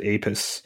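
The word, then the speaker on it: Apess: Yeah, most scholars that I've heard pronounce it Apis.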